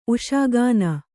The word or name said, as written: ಉಷಾಗಾನ